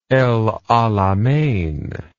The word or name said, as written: El Alamein